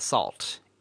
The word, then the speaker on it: NaCl: And so you're supposed to pronounce NACL as salt.